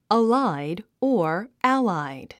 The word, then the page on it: allied: əláid; アライド